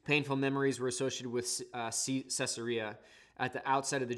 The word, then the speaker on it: Caesarea: Caesarea, which basically it's spelled Caesar with an E-A on the end, Caesarea was the name he gave it.